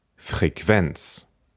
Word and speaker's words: Frequenz: Das Wort Frequenz Wir haben K-O in der Mitte und die werden Kw ausgesprochen. Deswegen sagt man Frequenz oder Qualität oder Qualle.